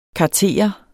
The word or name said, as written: kartere